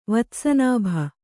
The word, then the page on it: ವತ್ಸನಾಭಿ: vatsa nābhi